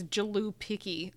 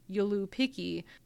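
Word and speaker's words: Joulupukki: Or it's, I don't think it's with, because it starts with a J, but I don't think you pronounce the J as J. So I don't think it's Jalupiki. I think it's Yulupiki.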